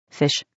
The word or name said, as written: fish